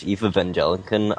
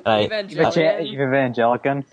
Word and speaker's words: Evangelion: Once I finish Eve Evangelion, I will. – Evangelion.